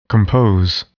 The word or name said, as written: compose